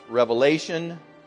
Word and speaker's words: Revelation: You probably heard me say this before, but the last book of the Bible is pronounced Revelation. There is no S on the end of that. There's only one Revelation, the Revelation.